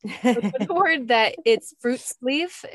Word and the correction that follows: friluftsliv: Is that how you say it? – That's pretty close. – Okay. – And don't worry, you're in good company. Everybody worries about butchering this word. But yeah, friluftsliv.